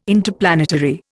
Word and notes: Interplanetary: Indian

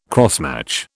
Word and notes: crossmatch: British English

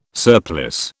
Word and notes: surplice: British English

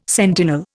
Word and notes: sentinel: Indian

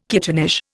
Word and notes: kittenish: Indian

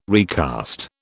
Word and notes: recast: British English

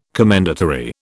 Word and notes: commendatory: British English

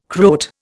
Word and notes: Croat: Indian

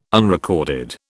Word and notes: unrecorded: British English

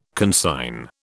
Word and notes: consign: British English

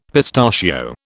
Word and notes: pistachio: British English

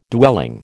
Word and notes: dwelling: American English

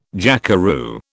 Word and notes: jackeroo: British English

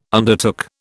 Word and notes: undertook: British English

